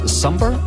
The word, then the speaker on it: SOMBR: Det staves S-O-M-B-R, det udtales Somber, og sangen her hedder Homewrecker, du fik den på Radio 100 en onsdag aften.